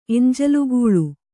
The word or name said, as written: ಎಂಜಲುಗೂಳು